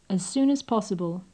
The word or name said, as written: as soon as possible